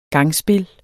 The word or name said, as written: gangspil